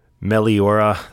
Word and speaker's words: Meliora: But again, laundry list, the new Ghost record, Meliora. That's at least how I say it. How do you say it? Is that how you pronounce it, Joey? – The exact same way.